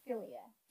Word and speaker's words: philia: Philia.